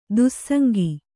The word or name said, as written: ದುಸ್ಸಂಗ